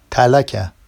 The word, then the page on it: talaca: Vafsi